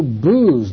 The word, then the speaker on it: Boaz: B-O-A-Z, pronounced in Hebrew, Booz. Not Boaz, it's a wrong translation.